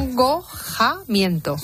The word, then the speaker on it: acongojamiento: Del acongojoagamiento. No sé si lo he dicho bien, ¿no? – No, es que has mezclado dos palabras. – Acongojamiento.